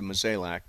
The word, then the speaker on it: Mozeliak: Yeah, I mean, if you have a vision, if you're John Mozeliak, I think is the way you pronounce it, Mozeliak,